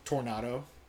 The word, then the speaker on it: Tornado: He's just saying Tornado, like pronouncing it wrong? – No. – That's embarrassing. – No, it's just the way that it was. – Oh, wait, is that how you say Tornado in Spanish? – Yes.